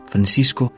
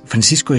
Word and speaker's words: Francisco: Francisco y Jacinta, dicen los portugueses. Le pedí a un amigo portugués que me dijera cómo se pronunciaba. Pues Francisco y Jacinta, ¿no?